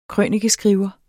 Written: krønikeskriver

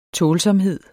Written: tålsomhed

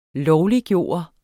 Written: LOVLIGGJORT

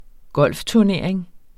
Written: golfturnering

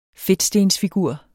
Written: fedtstensfigur

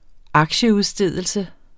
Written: aktieudstedelse

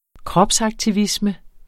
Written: kropsaktivisme

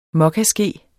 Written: mokkaske

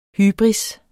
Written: hybris

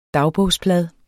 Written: dagbogsblad

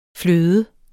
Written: fløde

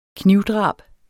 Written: knivdrab

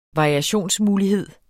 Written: variationsmulighed

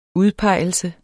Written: udpegelse

